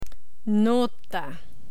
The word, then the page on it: nota: spanish